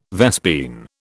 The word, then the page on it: vespine: British English